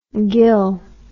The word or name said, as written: ghyll